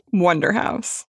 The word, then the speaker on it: Wunderhaus: This is the story of Wunderhaus, spelled the German way. – Like Wunderhaus? – This is America, so it's pronounced Wunderhaus.